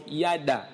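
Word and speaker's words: Yada (ידע): Quand la Bible dit Adam connut Ève, le mot connu ici, connaître, c'est un mot hébreu que l'on prononce Yada, Y-A-D-A, qui veut dire connaître intimement.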